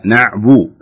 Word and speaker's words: نعبد: لا ليست أعبد. لا نعبد. نعبد. شوفي ضم الشفتين عند النطق الباء.